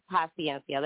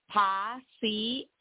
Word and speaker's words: paciencia: The I-E and the I-A at the end of paciencia, that last word, are pronounced separately. So the vowels in Spanish, when they're next uh-huh. – to each other, are pronounced separado, separately, okay?